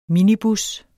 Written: minibus

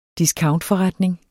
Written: discountforretning

